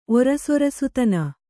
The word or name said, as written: ಒರಸೊರಸುತನ